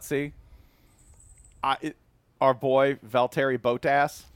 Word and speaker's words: Valtteri Bottas: our boy Valtteri Bottas. The official F1 driver of the full cast. – If you're going to say that he's ours, you have to say his name right. – Valtteri Bottas.